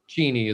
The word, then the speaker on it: Cheney: Dick Cheney is pronounced Cheney.